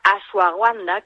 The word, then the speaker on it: ashwagandha: se llama ashwagandha. Vale, en realidad se escribe ashwagandha, pero bueno, es la ashwagandha,